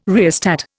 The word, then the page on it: rheostat: Indian